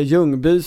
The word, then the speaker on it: Ljungby: Det finns en underbar lite strandtext från Ljungby, eller Ljungby som du vill säga, tror jag. det skånska uttalet av den här småländska orten.